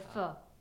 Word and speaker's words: pho: Pho, or pho, as it's properly pronounced.